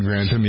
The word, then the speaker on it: Grantham: Is that how you say his name? Grantham? – Lord Grantham, yeah.